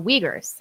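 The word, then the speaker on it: Uighurs: It's like U-I-G-H-U-R. That's one of the. – The Uyghurs.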